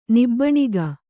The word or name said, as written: ನಾಭಿನಗ